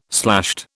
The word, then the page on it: slashed: British English